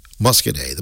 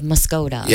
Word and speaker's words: Muscoda: And again, don't miss it, the Muscadet Morel Mushroom Festival this very weekend in the one and only Muscadet, the most commonly mispronounced town in the state of Wisconsin, I think. – It is. They call and say the Village of Muscoda.